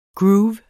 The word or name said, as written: groove